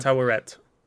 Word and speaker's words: Tawaret: Oh, Tawaret. I said it right.